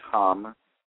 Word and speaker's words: ham: Y-A-M, and then you have the throat chakra, which is ham, H-A-M.